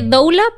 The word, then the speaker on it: doula: Se escribe doula, pero doula, doula.